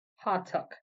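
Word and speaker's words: Hotuk (호떡): Hotuk.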